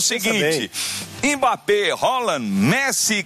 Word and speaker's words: Haaland: Que não é Haaland, viu? Ele ensinou. Foi ele que ensinou. Ele falou: a pronúncia do meu nome é Haaland.